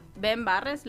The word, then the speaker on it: Ben Barres: Ben Barres, ¿lo dije bien? Sí.